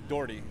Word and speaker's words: Daughtry: But the way I pronounce it, or my family pronounces it, is like door, like a door you open, T-Y, Doherty.